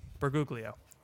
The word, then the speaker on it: Bergoglio: Bergoglio.